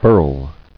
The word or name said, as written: Burling